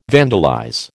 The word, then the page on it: vandalize: American English